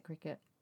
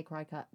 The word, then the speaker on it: Cricut: She got one of those cricket, I think you say it cricket. I used to say cricut, but it's spelled cricut, but it...